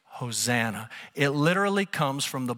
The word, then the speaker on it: Hosanna: The Greek word here is pronounced, are you ready for this? Hosanna.